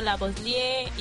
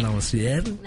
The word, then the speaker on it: Lavoisier: Esta nos habla sobre los descubrimientos del oxígeno y sobre aquellos estos científicos que tengo por aquí: Joseph Priestley, Antonio Lavoisier y Shelley. – Lavoisier.